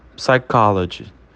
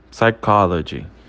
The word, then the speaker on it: psychology: E por último, psychology, psychology, psychology, tá? O P, ele é bem levinho ali e a gente considera ele mudo.